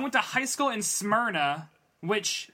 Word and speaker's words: Smyrna: Smyrna was the way to pronounce. Anyway. – Smyrna.